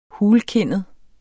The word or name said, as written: hulkindet